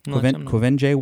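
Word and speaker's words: Quvenzhané: Kwenje Wallace. Although it's not spelled that way. That's the way I was told to pronounce it on Wikipedia.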